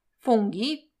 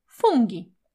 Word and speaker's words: funghi: Fungi piszemy przez GH, literka H umiejscowiona pomiędzy G a I, powoduje, że G zachowuje twardą wymowę. Zatem grzyby to po włosku fungi, a nie fungi.